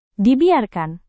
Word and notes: dibiarkan: Indonesian

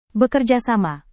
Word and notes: bekerjasama: indonesian

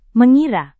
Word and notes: mengira: Indonesian

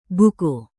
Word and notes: buku: indonesian

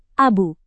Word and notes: abu: indonesian

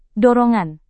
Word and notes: dorongan: Indonesian

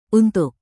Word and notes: untuk: indonesian

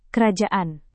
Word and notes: kerajaan: indonesian

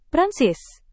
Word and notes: prancis: Indonesian

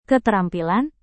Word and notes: keterampilan: indonesian

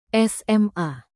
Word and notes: sma: Indonesian

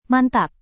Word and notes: mantap: Indonesian

